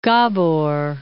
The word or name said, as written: Gabor